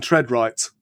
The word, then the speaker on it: TreadRight: Now, you are from the Travel Corporation and Treadright.